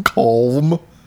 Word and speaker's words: calm: Calm. You got to pronounce the L. – Calm down.